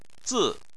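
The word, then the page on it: zi: tone 4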